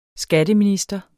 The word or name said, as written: Skatteminister